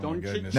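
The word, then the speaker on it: Dončić: Luka Doncic. – Luka Doncic. Is that how you say his name? – No, Doncic.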